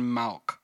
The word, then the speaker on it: Malk: Mando and him shake hands, and basically, the character's name is Rand Malk. M-A-L-K. So, Malk, I think is how you pronounce it.